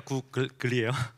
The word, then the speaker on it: 구글: 아, 왜냐하면 제가 발음이 좀 안 좋은 것 같아가지고 구글 뭐라고 해야 돼요? 구리 L이니까 구글이에요.